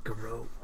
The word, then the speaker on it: garrote: How is it pronounced? – Please. – It's garrote. – Garrote? – Garrote. – Garrote. – You said a garionette. – Yeah, isn't that what it is? – Garrote.